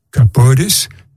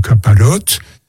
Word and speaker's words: kapores: Et donc, en yiddish, bien sûr, le terme garde ce sens, kapores, la prononciation ashkenaz, mais c'est comme kaparot, et on dit l'acte de sacrifier la volaille,